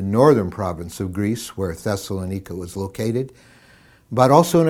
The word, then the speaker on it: Thessalonica: It's the first letter that we have that he writes to a church he planted in the city in northern Greece, the city of Thessalonica, or many people pronounce it Thessalonica.